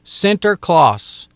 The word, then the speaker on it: Sinterklaas: but they pronounce it Sinterklaas. Sinterklaas, Santa Claus.